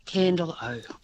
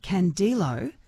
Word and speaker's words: Candelo: How do I say C-A-N-D-E-L-O? – It is pronounced Candelo.